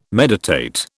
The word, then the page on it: meditate: British English